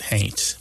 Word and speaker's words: haint: Somebody might spell it H-A-U-N-T, but pronounce it haint.